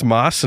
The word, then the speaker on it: Mads: So is it really pronounced Moss and not Mads? – It is pronounced Moss. I did some research.